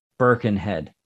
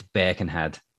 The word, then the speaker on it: Birkenhead: You guys would pronounce that Birkenhead. Yeah, Birkenhead. But it's Birkenhead. That's how I say it.